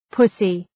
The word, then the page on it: pussy: ‘pʌsı